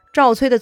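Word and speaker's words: 赵衰: 赵崔的崔其实呢是写成衰落的衰，念成崔。赵崔啊，嬴姓赵氏，字子瑜。他呢是后来晋国三分之后赵国国君的始祖，史称赵成子。赵崔跟重耳非亲非故，连远亲都不是啊。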